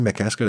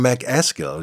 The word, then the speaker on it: McCaskill: Danny McCaskill, der Schotte. McCaskill. Ähm, könnte man ihn noch, noch äh, schottischer aussprechen vielleicht. Deutsche würden ja sagen Mac Askill.